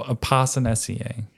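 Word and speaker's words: Apocynaceae: Okay, so Opossinaceae. Mm-hmm. That's how you say it.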